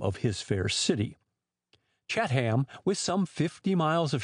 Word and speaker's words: Chatham: So the proper pronunciation for the town of Chatham is Chat-ham, with the accent on the hind end.